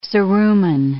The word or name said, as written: cerumen